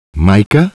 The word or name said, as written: Micah